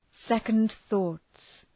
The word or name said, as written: second thoughts